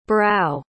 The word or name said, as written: brough